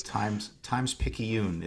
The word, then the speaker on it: Times-Picayune: Times-Picayune is how you say the name of that newspaper.